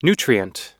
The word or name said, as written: nutrient